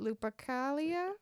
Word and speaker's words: Lupercalia: Yeah, if we really want to like take it back to ancient Romans and the feast of, I don't know how you say it, Lupercalia? – Yep. – Is that it? – Yeah, that's what they said on YouTube.